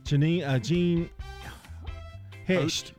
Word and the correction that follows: Hecht: Hoecht. – Hoecht.